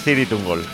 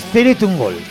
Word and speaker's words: Cirith Ungol: Es en Sindarin y es Ciritungol. – Ciritungol, vale, ¿no? Entonces no es una... – No se pronuncia como dicen los... Es Ciritungol.